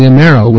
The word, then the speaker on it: Amero: And the plan now at BIS and with the rest of the globalists, their next mission is the creation of what's called the Amero. If I pronounce that right, Patrick? – That's correct. – Well, the Amero,